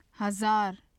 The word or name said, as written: hazar